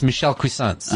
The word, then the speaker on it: Cuisance: I think he's pronounced Michel Cuisance.